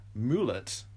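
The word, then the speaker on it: Moult: So, an Order of Nine Angels training manual written by leader Richard Moulet. I think that's how you pronounce it. It's M-O-U-L-T.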